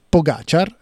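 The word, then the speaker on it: Pogačar: si dice Pogacar, così ha detto che questa sarebbe la pronuncia corretta, ecco, e gli altri sono piuttosto staccati.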